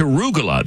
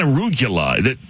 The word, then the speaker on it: arugula: But man, it's arugula. There, it's not arugula. He's adding syllables.